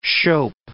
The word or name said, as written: scop